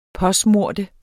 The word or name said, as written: påsmøre